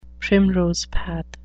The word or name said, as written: primrose path